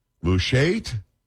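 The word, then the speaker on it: Khamis Mushait: I don't know how to say this. M-U-S-H-A-I-T. Mushait? Can we say it? We're going to go with that. Paul, are we good with that?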